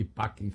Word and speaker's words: pacem: E pacem se escreve passem, com C, mas é que tinha provavelmente som de K.